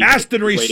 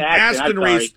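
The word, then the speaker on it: Aston-Reese: Yeah, I like the big body Austin Reese in there. I think that would be Aston Reese, sir. Don't mispronounce his name. Aston Reese.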